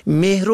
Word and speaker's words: مهر: و یا کلمه مهر و مهریه را که حق دختر عروسی شده است مهر و مهریه می خوانند، که نادرست است. این کلمه مهر است به فتح میم، نه به کسر میم تلفظ کنیم.